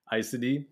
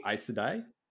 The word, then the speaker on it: Aes Sedai: So give give that pronunciation to me one more time. Icedi. – Icedi? Icedi? – Icedi? Yeah, Icedi. I'm saying that right now.